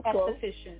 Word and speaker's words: esthetician: But esthetician, you were very close, but that's the way you pronounce it. – I was esthetician.